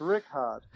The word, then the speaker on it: Richard: My last two running backs, I've got Mark Ingram and Jalen Ricard. I know. – It's spelt Richard. Why is it pronounced Ricard? – I know. It's Rickard.